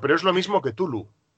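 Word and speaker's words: Cthulhu: Parece ser que es Tulu, pero antes de la T en una especie de C aspiada. Sería correctamente, sería Tulu. Sin llegar a pronunciar la A de Catulu.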